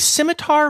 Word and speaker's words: scimitar: But yeah, it gets to a weird place with some of these longer names, like scimitar horned oryx, right? Okay, so the first word is scimitar, so the first syllable is sim, so would it be a simlet?